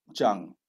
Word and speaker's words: Zhang: Well, first of all, congratulations on pronouncing my surname spot on, Zhang. It's the third most popular Chinese surname. So So fantastic. Thank you for showing people how it's pronounced properly.